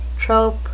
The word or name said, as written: trope